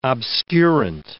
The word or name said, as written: obscurant